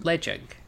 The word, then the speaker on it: Ledaig: So it's spelled L-E-D-A-I-G, and it's pronounced Ledig.